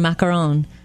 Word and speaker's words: macaron: Look at any recipe for a macaron. Yeah. Trust me. – It's how pronounces it differently every time she says it. – I'm trying to remember what the Google lady said. Macaron.